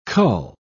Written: cull

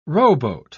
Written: rowboat

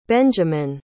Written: benjiman

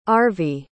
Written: Arvie

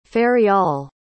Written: feryal

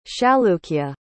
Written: chalukya

